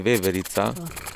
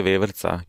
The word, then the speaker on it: veverica: You know that's the thing I say veverica, but in when you speak like like normally you say veverca.